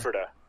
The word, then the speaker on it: Ephrata: It's Ephrata. – Ephrata? – It's spelled Ephrata, but it's Ephrata, yeah.